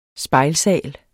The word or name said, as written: spejlsal